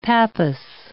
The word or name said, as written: pappus